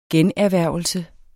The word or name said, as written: generhvervelse